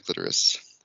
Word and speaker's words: clitoris: I asked if she knew the physiological purpose of the clitoris. She didn't. Am I saying that right? Clitoris. It sounds weird. – Am I saying it sounds weird that you're saying it. Yes, you're saying it right.